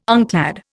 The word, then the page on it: unclad: Indian